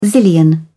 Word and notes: zillion: British English

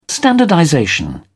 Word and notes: standardization: British English

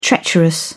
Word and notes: treacherous: British English

